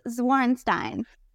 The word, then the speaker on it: Zwarensteyn: Am I close, Jill? – You're close. It's Zwarenstein.